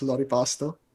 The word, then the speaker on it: Loripasto: Um, there is like the American pronunciation, which is kind of distorting the name, but it's fine. It's Lori Pasto, more okay.